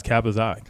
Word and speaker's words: KappaZah: Am I saying that right, or is it Kappa? – Yeah, okay. – No, it's Kappa Zah. K-A-P-P-A-Z-A-H. – Z-A-H.